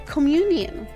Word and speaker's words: communion: La primera lo pueden llamar así communion. Communion. Y se escribe C-O-M-M-U-N-I-O-N. Una vez más, se escribe de esta manera: c o m m u n i o -N, Communion,